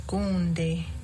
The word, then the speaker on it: gonde: And going back to eel, gonde. – Gonde.